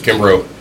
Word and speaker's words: Kimbrough: My last name's Kimbrough, and it's spelled exactly how it sounds.